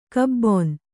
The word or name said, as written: ಕಬ್ಬುನ